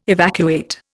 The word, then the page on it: evacuate: Indian